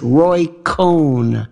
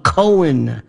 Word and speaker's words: Cohn: It was, of course, Roy Cohn. And by the way, he's one of the most incredibly mispronounced names. They call him Cohen.